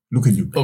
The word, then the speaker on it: Lukeniuk: Og han flytter ind i et fællesskab med tre piger, Jenna Little, Sadie Burgard og Jasmine Lukenyuk. L-U-K-E-N-I-U-K. Lukenyuk.